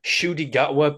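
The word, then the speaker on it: Ncuti Gatwa: It is Shudi Gatwa, but I think you spell it Nakuti.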